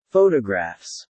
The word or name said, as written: photographs